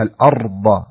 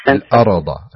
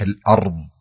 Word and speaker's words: الأرض: الثانية في قوله تعالى الله الذي جعل لكم الأرض، لا تقول الأرض الأرى أرى، لا خليها الأرض، أمسك الراء حيث أن يكون سكون لا يكون فيه مبالغة في النطق الراء حتى أنا لا يعني يستم يظن السامع أن في قلقلة. الأرض الأرض خطأ، الصحيح الأرض الأرض الأرض.